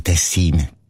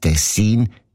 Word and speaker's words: Tessin: Aber der SRF-1-Hörerin Regula Stern-Grieser von Locarno ist aufgefallen, dass gewisse Leute statt Tessin Tessin sagen, also Betonung auf ein Meh, mit einem kurzen I.